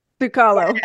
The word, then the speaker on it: Sukalo: Sukalo.